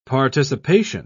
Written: participation

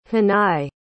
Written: hanaye

